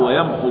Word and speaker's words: يمح: هذه أربعة أفعال في القرآن يظن القارئ أن بعد العين واو وهي ليست كذلك، أو بعد حاء ويمحو الله واو ليست كذلك، ولكن محذوفة في الرأس فتحذف بالنطق، فأقول ويمح ويدع.